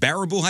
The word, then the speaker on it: Baraboo: Students at Barbaro High School. I'm sorry, I'm saying that wrong. Is it Baraboo High School? B-A-R-A-B-O-O, Baraboo.